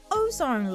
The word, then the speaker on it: ozone: Ozone layer。 – カタカナではオゾンですが、英語の発音はオゾーンといった感じです。